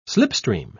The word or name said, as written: slipstream